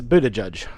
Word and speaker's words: Buttigieg: It's Buttigieg.